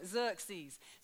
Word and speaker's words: Xerxes: I'm told that's how you say it, so I'm going with that, King Xerxes. So the book opens with this king of this vast kingdom. And it tells us that for 180 days, which is six whole months, King Xerxes has gathered his nobles,